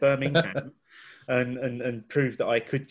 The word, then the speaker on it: Birmingham: Teach you how to pronounce Birmingham, because there is a G in it. Or Brummagem, as I would say.